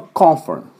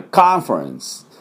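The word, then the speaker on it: conference: ですから、当然イギリス人はコンファレンス、コンファレンス、コンファレンスって言うと思いますが、アメリカ人はカンファレンス、カンファレンスと発音します。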